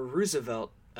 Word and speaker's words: Roosevelt: Actually, Theodore Roosevelt, preferred his name pronounced Roosevelt. Franklin Delano Roosevelt was Roosevelt, even though they were related. It was pronounced differently for whatever reason.